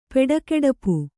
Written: ಪೆಡಕೆಡಪು

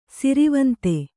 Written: ಸ್ರವಂತಿ

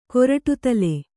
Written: ಕೊರಟುದಲೆ

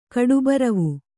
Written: ಕಡುಬರವು

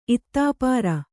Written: ಇತ್ತಾಪಾರ

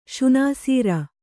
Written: ಶುನಾಸೀರ

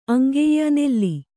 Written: ಅಂಗೆಯ್ಯ ನೆಲ್ಲಿ

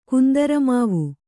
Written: ಕುಂದರಮಾವು

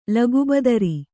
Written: ಲಗುಬದರಿ

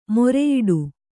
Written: ಮೂರಿಯಿಡು

